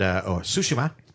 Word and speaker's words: Tsushima: And Tsushima, as it's pronounced in the game.